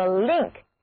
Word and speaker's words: link: 还有一个单词叫做 link，意思也是连接、联合。注意字母 N 在这里读作长鼻子的 O， link。